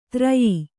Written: ತರಾಯಿ

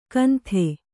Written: ಕೌಂದ

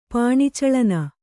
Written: ಪಾಣಿಚಳನ

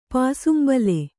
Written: ಪಾಸುಂಬಲೆ

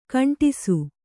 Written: ಖಂಡಿಸು

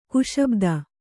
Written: ಕ್ಷೋಭಿತ

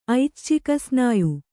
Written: ಐಚ್ಚಿಕಸ್ನಾಯು

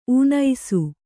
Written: ಊನೈಸು

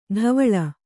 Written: ಠವಳ

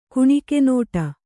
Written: ಕುಣಿಕೆನೋಟ

